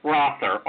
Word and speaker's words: Rother: Actually, my name is pronounced Rother, R-O-T-H-E-R, Rother, and I am movie guy 24/7.